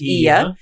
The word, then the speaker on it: Oia: Ia spelled O-I-A, which we kept calling it Oia, but it's actually pronounced Ia.